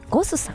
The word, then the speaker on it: ゴス: ゴスさん、 GOSS と書いてね、ゴスさん、そしてお寿司屋さんが 2店舗、お魚のブリにもんと書きましてシモンというお店が銀座に 1 店舗、日本橋に1 店舗ございます。